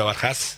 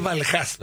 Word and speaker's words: Barjas: Es Barjas, ¿no? – Es Barjas. – Se escribe de otra manera, pero Barjas, ¿no? – Sí, en realidad es la H aspirada, porque el apellido es de origen sirio-libanés.